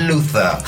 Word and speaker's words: Luther: ¿Cómo lo pronuncia Idris Elba? – Luther.